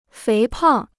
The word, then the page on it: 肥胖: féi pàng